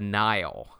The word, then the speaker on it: Nihil: So the main antagonists of the High Republic series will be a group of space pirates called the Nile. – Yeah. – Yeah. – And it's not spelled like the Nile River. It's spelled like N-I-H-I-L, which is kind of a root for the word nihilistic.